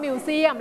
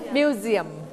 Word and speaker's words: museum: อันนี้ S อยู่ระหว่างสระ 2 ตัวเสียงตัว S เวลาเราออกเสียงให้เปลี่ยนเสียงเป็นเสียงตัว Z เสียงตัว Z ทันทีดังนั้นเราไม่ออกเสียงว่า museum เราเสียงว่า museum